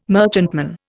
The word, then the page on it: merchantman: Indian